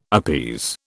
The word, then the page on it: appease: British English